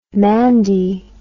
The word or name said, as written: mandy